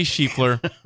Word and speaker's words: Scheffler: It's Scheffler. You say Scotty Scheffler. – Scotty Scheffler. – I had to stop the music because you said the name wrong.